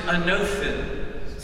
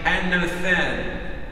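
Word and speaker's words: anothen (ἄνωθεν): In the Greek, you pronounce it anothen. Some say anothen.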